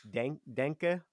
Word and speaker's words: Denke: So it's pronounced denke, positive.